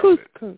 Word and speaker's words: couscous: C-O-U-S. C-O-U-S dish. I don't know how to pronounce couscous. – It. Couscous. – Right? – Right. Very good.